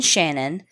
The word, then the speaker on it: Chanin: The Starbuck family was made up of Clay and Shannon, and Shannon's name is spelled C-H-A-N-I-N.